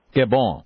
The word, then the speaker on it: Gabon: Gabon.